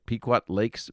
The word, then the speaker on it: Pequot Lakes: Pequot Lakes, Minnesota, listening on KCFB. Did I say the name of your town correctly? – That's correct.